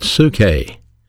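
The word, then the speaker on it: psuche (ψυχή): The word soul is pronounced in the Greek New Testament, psuche.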